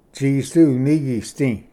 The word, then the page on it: ᏥᏍᏚ ᎤᏂᎩᏍᏗ: tsisdu-unigisdi